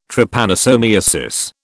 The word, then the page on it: Trypanosomiasis: British English